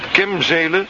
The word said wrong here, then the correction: Kemzeke: Nee, Kemzeken. Kemzeken.